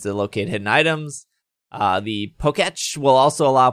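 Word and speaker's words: Pokétch: How do you say it? – Pokeech. – Pokeech. That's a Pokeech. – Like Etch a Sketch.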